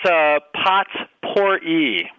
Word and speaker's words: potpourri: I think it's pronounced potpourri. – Potpourri. – Yes. Pretty sure that's right.